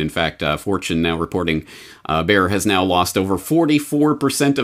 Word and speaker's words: Bayer: It's technically pronounced Bayer, but I'm Canadian, so I'll say Bayer.